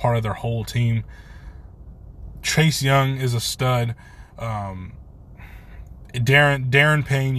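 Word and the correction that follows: Da'Ron Payne: yes, I was thinking how to say his name. I think it's Daron Payne.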